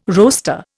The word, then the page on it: roaster: Indian